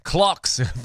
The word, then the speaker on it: Clocks: Coldplay Cox. Oh, geez, Louise. Clocks. Really got to pronounce the L in that one. It really caught me slipping.